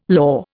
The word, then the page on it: lor: Indian